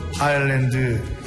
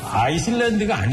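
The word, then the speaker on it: Islands: 제목은 그 아일랜드 인더스트리. – 아이슬란드가 아니죠. – 뭡니까? – 아일랜드죠. – 아일랜드니까? 왜 근데 아이슬란드는 묵음이죠, 묵음.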